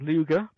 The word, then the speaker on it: Ludger: You play as a guy called Luger, spelt Ludger, but pronounced Luger because it can.